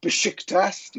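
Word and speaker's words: Beşiktaş: Manning the ramparts for New York Besiktas, to give it the correct pronunciation.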